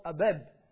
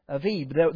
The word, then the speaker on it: Aviv: Now I know it's called Abib, but in Hebrew it's called Aviv. Aviv, those B's are pronounced V's, so I'm told or so I've read.